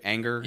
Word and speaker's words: Ehinger: They traded him to Kansas City for, and Jerry Jones talked about this morning, third-year offensive lineman Parker Anger. I think I'm saying that right. – Anger, right? – Anger. – Yeah, I think that's right. – E-H-I-N-G-E-R.